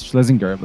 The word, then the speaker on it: Schlezinger: It was almost correct. It was Schlezinger, but that's fine.